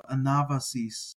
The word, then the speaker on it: Anabasis: You'll hear people say Anabasis, and I'm using the, you know, I pronounce the Veta as sort of the Beta, if you want to say it that way, with a B sound. So Anabasis.